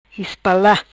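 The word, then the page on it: Ispala: Patuá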